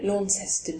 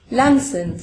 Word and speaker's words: Launceston: He lived in, I call it Launceston, but I think it's actually pronounced completely differently. How's it pronounced? Lanson.